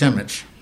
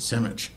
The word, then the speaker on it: Simic: Also pronounced Simic. If you're Serbian, you pronounce it Simic.